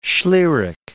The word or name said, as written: schlieric